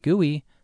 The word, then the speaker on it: GUI: The GUI, pronounced GUI, stands for the Graphical User Interface.